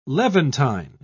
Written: levantine

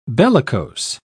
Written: bellicose

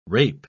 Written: rape

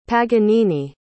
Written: paganini

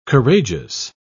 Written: courageous

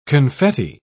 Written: confetti

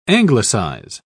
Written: anglicize

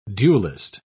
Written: dualist

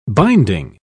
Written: binding

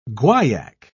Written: guaiac

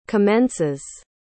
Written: commences

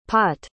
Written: pott

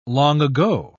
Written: long-ago

